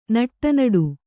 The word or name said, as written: ನಡನಡ